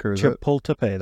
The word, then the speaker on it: Chapultepec: Chapinse Peace Accords. – Was it Chapultepec? – Chapultepec, that's it.